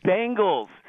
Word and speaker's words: Bengals: Hey, Big Head, just because there's an E in the word doesn't mean you have to pronounce it. You got that, you penis. It's Bengals. Bang, bang, Bengals.